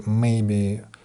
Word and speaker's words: maybe: В речи, кстати говоря, в слове maybe ударение идет на первый слог на may, а в, глаголь, в, сложном глаголь, в сложной глагольной фразе maybe ударение больше идет все-таки на смысловой глагол, в данном случае на глагол be.